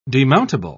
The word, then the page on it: demountable: British English